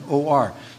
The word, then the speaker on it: Nicanor: the gate of Nicanor, I believe it's pronounced, Nicanor.